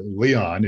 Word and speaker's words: Leon: I keep hesitating because I had a friend by the name of Roger Leon, but Leon is how you pronounce it.